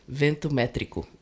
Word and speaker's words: Ventometrico: Vento or Vento? – Ventometrico. – Okay, sounds better than I pronounce it.